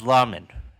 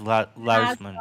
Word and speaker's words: Luhrmann: Baz Luhrmann. I totally butchered his name. – Yeah. – Baz Luhrmann.